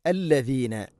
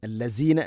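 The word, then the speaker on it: അല്ലദീന (الذين): അല്ലദീന, അല്ലസീന അല്ല, അല്ലദീന ആമനുത്തഖുല്ലാഹ്, ആമനു ഇത്തഖുല്ലാഹ്.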